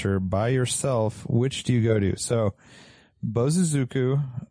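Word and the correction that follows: bōsōzoku: I'm saying that wrong. Bozozoku.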